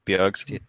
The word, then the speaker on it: Bjergsen: Yeah, it's really hard because Bergson... Bjergsen, how do you pronounce that? – Bjergsen.